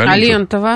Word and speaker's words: Алентова: как ее правильное ударение? – Алентова. – Алентова? – Всю жизнь была.